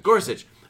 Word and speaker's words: Gorsuch: Gorsuch.